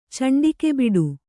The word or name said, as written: ಚಂಡಿಕೆಬಿಡು